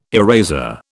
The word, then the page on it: eraser: British English